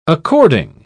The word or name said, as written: according